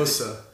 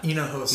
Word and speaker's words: Hinijosa: Hinijosa. – Huh? – Hinijosa. – Now that's spelled with an H, but the H is silent. So Hinijosa.